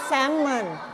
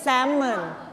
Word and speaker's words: salmon: อ่านว่า salmon salmon ไม่ออกเสียง L นะ